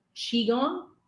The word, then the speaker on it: Qigong: So is it through that, the Qigong. Did I say it right this time? – Yeah.